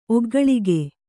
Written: ಒಗ್ಗಳಿಗೆ

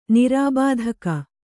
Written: ನಿರ್ಬಾಧಕ

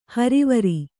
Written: ಹರವರಿ

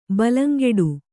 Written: ಬಲಂಗೆಡು

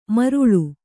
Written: ಮಾರಾಳ್

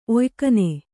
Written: ಒಯ್ಕನೆ

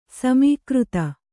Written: ಸಮೀಕೃತ

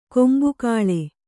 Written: ಕೊಂಬುಗೊಳ್

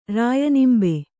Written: ರಾಯನಿಂಬೆ

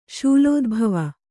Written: ಶೂಲೋದ್ಭವ

